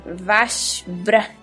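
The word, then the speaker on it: Vashbrax: Vashbra. O X no final. X é mudo, gente.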